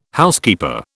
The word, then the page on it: Housekeeper: British English